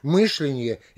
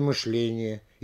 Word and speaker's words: мышление: До сих пор никто не может с уверенностью сказать, как будет правильнее произнести мышление или мышление, иначе или иначе, творог или творог.